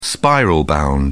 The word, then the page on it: spiral-bound: British English